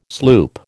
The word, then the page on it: sloop: American English